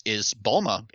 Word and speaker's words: Bulma: Another really good example is Bulma, actually. – Right. – The way that the Japanese pronounce her name, it sounds like bloomers, which is a girl's underwear.